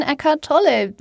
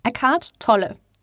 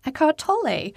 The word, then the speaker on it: Eckhart Tolle: even Eckhart Tolle, I'm already saying it with an English accent. Eckhart Tolle. Okay, I know German. I don't have to pretend I don't know how to pronounce his name.